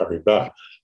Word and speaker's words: Paribas: You pronounced it properly. Thanks very much. – Yeah, correct pronunciation is Paribas.